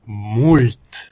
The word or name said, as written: mult